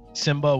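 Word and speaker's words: Symba: Simba with the Y. – Simba with a Y, okay. Yeah. I thought you said Samba like that.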